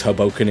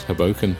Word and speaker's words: Hoboken: I think it's Hoboken. It might be Hoboken. – It's Hoboken.